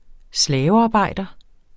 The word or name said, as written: slavearbejder